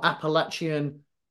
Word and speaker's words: Appalachian: And I saw that you had been on a sebabical doing the, I couldn't pronounce it the other day, still can't pronounce it, Appalachian or Appalachian Trail. – You got it. That's perfect.